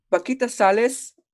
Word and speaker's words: Paquita Salas: I think it's Paquita. – Paquita Salas? – Paquita Salas. Yes, that sounds correct.